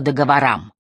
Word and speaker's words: договорам: «Сейчас в основном говорят «по договорам». Ударение на «а».